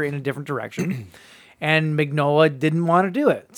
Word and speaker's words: Mignola: I'm on his Wikipedia right now, and if I'm reading the phonetic spelling of his name right, it's Mignola. – Mm-hmm. – So it is exactly the way it's spelled.